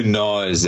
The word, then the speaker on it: gnaw: You know, I'm always being a smart ass because there's the silent G in front of gnaw and all that.